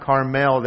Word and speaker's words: Carmel: Another amazing feat is that Herod built a 13-mile-long aqueduct outside the city to bring fresh water from Mount Carmel, or Mount Carmel, they usually pronounce it over there.